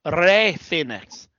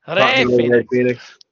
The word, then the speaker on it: Rey Fénix: It's pronounced Rey Fénix. Yeah. You say it like Excalibur says it. Rey Fénix.